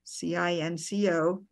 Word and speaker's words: cinco: Four is spelled C U A T R O, and it's cuatro, cuatro five, C I N C O, it's, it's spelled, and it said cinco, cinco.